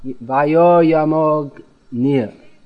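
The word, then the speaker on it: वायुर्यमोऽग्निर्: Don't insert an extra syllable. No. Gna, you have to say Vayoyamognir. No. Vayoyamognir.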